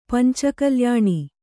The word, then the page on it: ಪಂಚಕಲ್ಯಾಣ: panca kalyaṇa